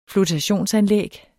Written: flotation

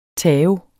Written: tao

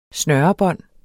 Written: snørebånd

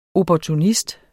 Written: opportunist

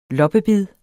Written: loppebid

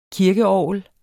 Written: kirkeorgel

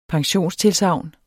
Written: pensionstilsagn

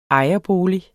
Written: ejerbolig

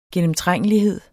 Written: gennemtrængelighed

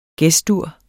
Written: Ges-dur

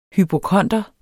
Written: hypokonder

